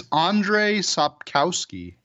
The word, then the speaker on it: Andrzej Sapkowski: I believe it's Andrzej Sapkowski.